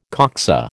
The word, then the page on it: coxa: American English